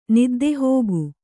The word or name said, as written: ನಿದ್ದೆಹೋಗು